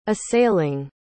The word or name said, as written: assailing